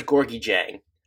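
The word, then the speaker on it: Gorgui Dieng: I'm pretty sure it's Georgi Jang. It changes when you're watching away broadcasts, but I think that's the proper pronunciation. – I'm looking it up now on Basketball Reference, and you are correct. Georgi Jang.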